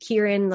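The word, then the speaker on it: Kirin: And if you think Kieran like the Japanese beer, so if you've seen that, it's spelled just the same.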